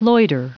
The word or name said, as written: loiter